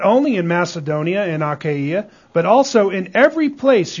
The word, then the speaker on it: Achaia: By the way, it's Achaia. If you were wondering if it was Achaia. It's not Achaia, it's Achaia. Okay? Look in the book. look in the Greek pronunciation book. And you'll see that that's why I keep calling it Achaia.